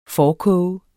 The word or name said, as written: forkoge